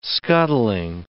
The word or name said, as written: scuttling